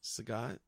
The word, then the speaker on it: Sagat: Is that how you say it? – Sagat? – Yeah. I thought it was Saget.